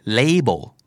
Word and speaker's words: label: จริงๆควรจะออกเสียงว่า label ไม่ลานะฮะ label l a b e l